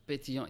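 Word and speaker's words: Pétillant: are drinking the, help me with the pronunciation, Pétillon Naturel. – Pétillon, yes.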